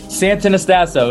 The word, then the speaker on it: Santonastasso: I got to say your last name correct. You got to tell me. – Santonastasso. It was close. It was good. – Santonastasso. – Yeah, perfect.